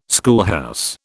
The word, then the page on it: schoolhouse: British English